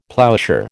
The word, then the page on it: ploughshare: American English